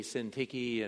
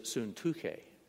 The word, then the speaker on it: Syntyche: I know we usually say Syntyche and we come up with all kinds of different ways, but I was taught when I studied Greek that in order to remember the words, you ought to pronounce them the way, as best as we understand, was said in first century Palestine and around the Mediterranean. And so it really is Suntuke.